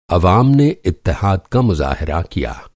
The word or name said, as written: इत्तहाद